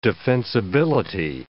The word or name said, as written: defensibility